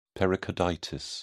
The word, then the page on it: pericarditis: British English